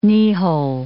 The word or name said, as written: kneehole